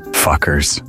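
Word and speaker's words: FCUKERS: The name of this rising electronic dance punk band is pronounced as Fuckers. Fuckers.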